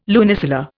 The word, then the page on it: lunisolar: Indian